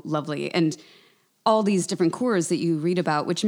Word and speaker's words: Cors: Am I saying that correctly? – Yep, Coors. C-O-R-S.